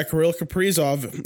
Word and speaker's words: Kirill Kaprizov: Yeah, Kirill Kaprizov, the actual pronunciation.